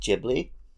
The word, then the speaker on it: Ghibli: I know it's meant to be pronounced Ghibli, but because they spell it with a G-H, which is always good, Ghibli. – I'm always correcting myself in the wrong direction. – I get it. In Spanish, it's the same, Ghibli.